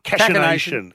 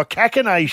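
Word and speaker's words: cachinnation: Cachination. Go the cack. – Oh, cackination. – Hard C. – I thought it was like cashing.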